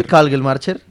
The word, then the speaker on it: Kagelmacher: Kagelmacher. – ¿Sí? – Kagelmacher. ¿Sí dice Kagelmacher? Kagelmacher. – Sí, Kagelmacher.